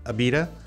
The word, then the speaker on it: Abita: Abita? – Yeah.